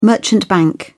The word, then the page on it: merchant bank: British English